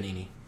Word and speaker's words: Benigni: how do you say his last name? – Benigni.